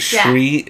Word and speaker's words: Sri: So it's like a SH sound. Shri. Yeah. But there's no H. What? – I know, but that's how it's pronounced.